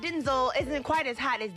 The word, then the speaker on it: Denzel: My name's actually Denzel. – It is not. – Yes, it is. I'm Denzel Hayes Washington Jr. My father was spelled the same way. His name was pronounced Denzel. My mother started saying Denzel, just made a difference.